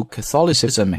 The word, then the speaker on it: Catholicism: Showcase is how Catholicism, 注意我们刚才读那个 Catholic, 这个重音是在第一个音节叫 Catholic。但是在读 Catholicism 的时候，我们的重音牵到了这个 thaw 上面。